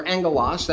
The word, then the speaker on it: angelos: When you look at the word angel or angelos, that would be how we would pronounce the original term, that simply means messenger.